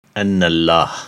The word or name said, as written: An-Allah